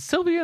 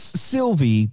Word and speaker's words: Sylvie: 19, Loki, and is it Sylvia? Is that how we're pronouncing the name? – It's Sylvie, but autocorrect always changes it to Sylvia.